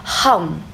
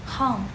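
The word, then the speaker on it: hum (हूँ): 在空中，在这个空元素，它在我们的传统中还对应相应的种子字，就是这基本的一个发音吧，就梵文的一个基本发音，它发音叫 hum hum。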